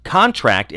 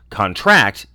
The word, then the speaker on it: contract: When a word has multiple meanings in virtually all classes, the noun form stresses the first syllable and verb stresses the last. For example, contract is a noun, contract is a verb.